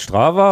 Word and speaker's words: Strava: Strava heißt Strava und Lupine heißt Lupine und Festiva heißt Festive.